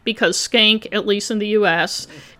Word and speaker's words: Shenck: And by the way, before I get too far in this, the high school is spelled S H E N C K, and it's pronounced skank.